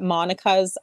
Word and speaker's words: Monika: So if people want to come find where we are, you can look up Monica's Art Boutique Studio and Gallery with a K.